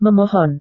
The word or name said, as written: memohon